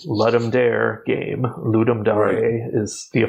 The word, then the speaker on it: Ludum Dare: Well, I mean, it's Latin, and I think the correct pronunciation is Ludum Dare, but, you know, no one does that.